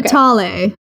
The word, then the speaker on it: Vitale: It says, this is from at Chrissy Vital. How would you pronounce that? I think that's right. – Okay. Vitale. – Chrissy Vitale. – I don't know. I'm joking. It's okay. V-I-T-A-L-E. Vitale. Chrissy Vitale. Wow, Chrissy, we are so sorry.